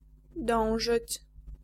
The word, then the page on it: s'efforcer: France; [e.fɔʁ.se]; /s‿e.fɔʁ.se/; /s‿ɛ.fɔʁ.se/